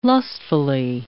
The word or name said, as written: lustfully